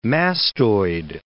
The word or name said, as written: mastoid